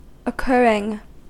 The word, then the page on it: occurring: American English